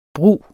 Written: brug

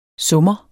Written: summer

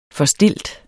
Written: forstilt

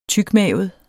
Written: tykmavet

